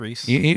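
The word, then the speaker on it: Rhys: and I don't want to say his name incorrectly, but Reese? – Yeah, it's Reese.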